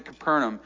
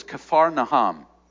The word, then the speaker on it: Capernaum: Uh, the distance from Capernaum, or, uh, and, and this is this is a moment, I'm, I'm going to continue to pronounce it Capernaum. The, the actual pronunciation of the word is Kafarnaham. Um, it means the village of comfort, all right? Um, but we all pronounce it Capernaum. Um,